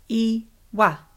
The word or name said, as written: iwa